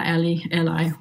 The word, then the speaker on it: A11y: Did I pronounce it correctly? – Yeah, Ally, whatever.